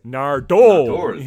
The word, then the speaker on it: Nardole: And I'll have to say, one of the things that really struck me in this story is actually Peter Capaldi's pronunciation of Nardole. – Yes. – It's a lot more like Nardole that he says. He's like, Nardole. – Well, it's Nardole, you know.